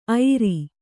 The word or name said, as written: ಐರಿ